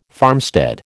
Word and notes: farmstead: American English

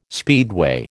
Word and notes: speedway: American English

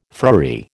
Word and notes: friary: American English

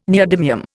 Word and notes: neodymium: Indian

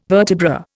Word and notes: vertebra: Indian

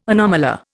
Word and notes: anomalure: Indian

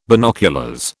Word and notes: binoculars: British English

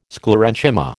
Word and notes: Sclerenchyma: American English

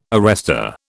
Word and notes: arrester: British English

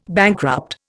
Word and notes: bankrupt: Indian

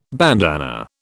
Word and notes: bandanna: British English

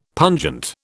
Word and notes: pungent: British English

